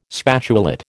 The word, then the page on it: spatulate: American English